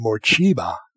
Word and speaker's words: Morcheeba: You've just listened to Morcheeba. That's how you pronounce that word.